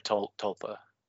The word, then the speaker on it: tulpa: You know, you're like sharing your Tupamancy because you're all agreeing upon the same tuppa. – And yeah, so, there's an L in there, tulpa. – Thank you, tulpa. I knew I mispronounced that.